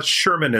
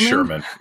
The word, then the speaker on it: Schuurman: Yeah, Schuurman is fine. Yeah. – Do you have another way? – Yeah, the Dutch pronunciation is too guttural, so you could stick Schuurman? With Schuurman. Yeah, something like that. – Yeah, for the audience, Schuurman in, you know, more English-oriented places is S-H-E-R-M-A-N, and yes.